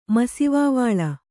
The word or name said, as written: ಮಸಿವಾವಾಳ